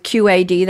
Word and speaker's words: QAD: And for our listeners, you may be familiar with QAD. That's three letters, all caps, no periods in between, and it's pronounced individually because we had the pleasure of welcoming Terry Onika from QAD on a show recently.